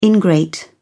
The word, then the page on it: ingrate: British English